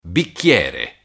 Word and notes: bicchiere: Italian

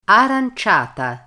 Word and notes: aranciata: Italian

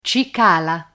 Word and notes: cicala: Italian

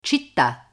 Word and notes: citta: Italian

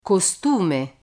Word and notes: costume: Italian

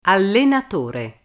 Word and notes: allenatore: Italian

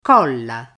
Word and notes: colla: Italian

